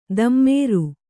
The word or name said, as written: ದಮ್ಮೇರು